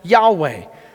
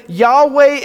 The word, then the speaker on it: Yahweh: And so our best guess is it would be pronounced Yahweh.